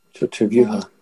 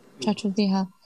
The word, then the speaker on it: Chaturvyuha: How do we pronounce that? – Chaturvyuha. – Chaturvyuha.